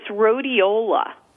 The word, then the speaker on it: rhodiola: One is rhodiola. Rhodiola, it's spelled R-H-O-D-I-O-L-A.